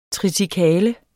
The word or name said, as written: triticale